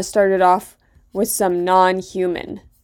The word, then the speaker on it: NNHMN: We started that set off there with a track by Non-Human, who spell their name. N-N-H-M-N. But it is pronounced non-human.